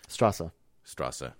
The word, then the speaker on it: Straße: Strasse. Yeah, the thing looks like a B. It's actually pronounced as two S's.